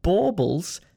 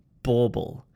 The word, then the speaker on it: bauble: So how do we pronounce this word, bauble? It sounds a little bit like bubble, but it's got an aw vowel sound. So it's bauble, bauble, bauble. Got it?